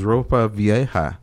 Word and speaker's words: ropa vieja: It was ropa vieja, and that was flank steak.